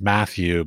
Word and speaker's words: Matthew: That's like here in the Czech Republic, you're like J's are pronounced like a Y. Mm-hmm. So, I mean, so sometimes it is just like character. Like my name is Matthew, but of course they call me Machu.